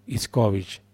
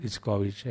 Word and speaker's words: Itzcovich: Norberto Iscovich, se escribe ITZCOVICH, Norberto Iscovich, es un economista.